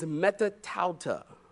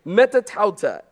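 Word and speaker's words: metatauta (μετὰ ταῦτα): The Greek word for after this is metatauta let me spell that for you. You need to write this down, it's very important. Metatauta, M E T A T A U T A, metatauta, after this.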